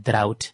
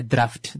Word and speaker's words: drought: And one thing that's known, every time that the first thing that comes to everyone's mind when we hear the word drought, not a draft, that's sometimes it can easily be mispronounced as it's the English language rules, and leave me alone.